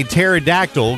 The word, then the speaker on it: pterodactyl: Why can't you hear a pterodactyl going to the bathroom? Well, because the P is silent. Because he spelled pterodactyl with a P. It's a silent P.